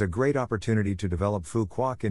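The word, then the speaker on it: Phú Quốc: The nature on Phu Quoc, pronounced Phu Quoc, was flourishing so richly that UNESCO designated the island a biosphere reserve in 2006.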